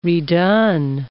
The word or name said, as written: redone